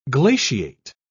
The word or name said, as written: glaciate